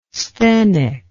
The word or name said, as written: sthenic